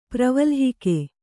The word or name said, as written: ಪ್ರವಲ್ಹಿಕೆ